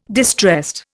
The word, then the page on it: distressed: Indian